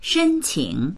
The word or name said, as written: shen1qing3